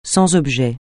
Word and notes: sans objet: francais

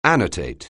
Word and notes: annotate: anglais

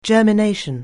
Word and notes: germination: anglais

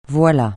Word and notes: voilà: francais

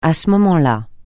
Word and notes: à ce moment-là: francais

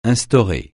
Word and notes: instaurer: francais

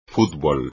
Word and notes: fútbol: espagnol

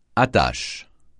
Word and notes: attache: francais

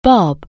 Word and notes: bob: anglais

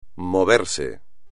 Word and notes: moverse: espagnol

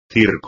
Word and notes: circo: espagnol

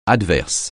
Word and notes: adverse: French

